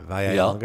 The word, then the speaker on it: Vallelonga: It looks like Vallelonga, but two L's in Italian is like a Y. Vallelonga? – Yeah.